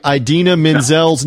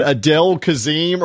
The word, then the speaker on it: Idina Menzel: is mispronouncing Idina Menzel's name from Frozen and is calling her, what was it, Adele Kazim or whatever, on worldwide TV.